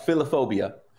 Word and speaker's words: philophobia: What is philophobia? Did I say that right? – Philophobia. – Philophobia. Okay. All right. Thanks for correcting me.